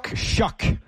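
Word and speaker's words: Shough: How do you pronounce it? Shuck. Wow, doesn't look like Shuck on paper.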